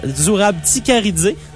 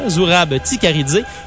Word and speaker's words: Zurab Tsikaridze: Zurab Tsikaridze. Attendez, il est écrit ailleurs. Zurab Tsikaridze. Donc, on ne prononce pas le S, très important. Zurab Tsikaridze.